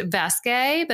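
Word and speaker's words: Vaeske: The brand is, I think it's pronounced Vasque, but it's V-A-E-S-K-E.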